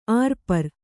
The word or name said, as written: ಆರುಪಾರ